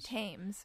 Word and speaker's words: Thames: So, first of all, how did I do in pronouncing the name of Emma Thames or Thames? – Thames. Mary Emma Thames.